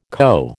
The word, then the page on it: co: American English